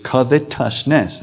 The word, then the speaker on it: covetousness: By the way, you don't pronounce that covetous. There's no E after the T as in righteous. It's covetousness.